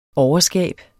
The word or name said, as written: overskab